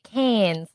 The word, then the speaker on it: Cairns: It's actually, we say Cairns, like C-A-N-S, yes.